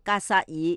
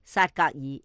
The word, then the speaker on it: 格萨尔: 并将格萨尔错读成萨格尔，